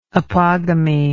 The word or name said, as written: apogamously